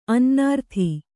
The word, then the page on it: ಅನ್ನಾರ್ಥಿ: Kannada